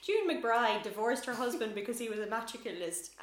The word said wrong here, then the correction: amatorculist: I keep forgetting how to say it. What did I say? – Amatorculist. – Amatorculist.